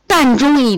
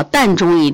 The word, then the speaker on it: 膻中: 在胸腹部膻中一点，不要念成痰啊，膻中一点，巨阙一点，气海。